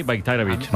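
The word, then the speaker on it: Bajraktarević: O sea, la J la conviertes en una I, Bayraktarevic.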